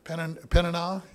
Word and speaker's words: Peninnah: How do you pronounce the first one? Peninnah, and then the second one was Hannah.